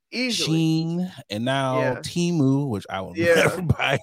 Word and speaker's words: Temu: I had to check a couple of videos to see how are they pronouncing this. Oh yeah, they're pronouncing this Temu.